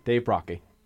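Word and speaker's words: Brockie: Dave Brocky. – Yep. – That's the way to say it.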